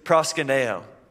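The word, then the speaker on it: proskuneo (προσκυνέω): The Hebrew word, so I listened to how you pronounce this like 20 times just so I could impress you guys, okay? shakah. And then the Greek word is proskuneo.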